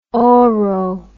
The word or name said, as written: oro